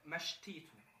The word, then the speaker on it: Mashtato: Äh, Mashtito. Mashtito. – Ja, genau. Mastito. – Das habe ich zumindest schon mal gut ausgesprochen. – Mastito. ja. Wird aber geschrieben Mastato. – Also M-A-S-H-T-A-T-O. Ganz genau.